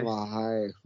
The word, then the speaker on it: Hayek: And that other girl, Salma Hayek, is it? Is that how you pronounce it? – Salma Hayek. – Hayek.